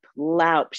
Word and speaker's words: loop: And she goes, it's loop, loup. They say loup, but it's spelled L-O-O-P.